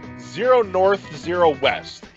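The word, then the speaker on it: 0°N 0°W: Zero North, Zero West is how it's pronounced. Specifically, it's like 0 degrees north, 0 degrees west.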